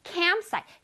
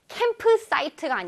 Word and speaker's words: campsite: campsite, campsite, 캠프사이트가 아니지, campsite, campsite.